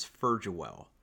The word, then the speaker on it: Ferjuel: My last name is Ferjuel. – Wow, Ferjual. – Yes. – All right. I would. It's the everyone goes with the hard G, the Ferg.